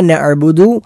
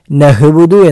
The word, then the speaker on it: نعبد: ഗൈനാകരുത്, നഅബുദു എന്നായാൽ അത് തെറ്റാകും. അതേപോലെ നഅബുദു എന്നോ നഹബുദു എന്നോ ആ ഹംസയോ ഹായോ ഒക്കെ ആയിട്ട് ഉച്ചരിച്ചാൽ അതെല്ലാം തെറ്റാണ്.